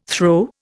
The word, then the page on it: throe: Indian